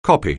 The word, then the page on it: copy: anglais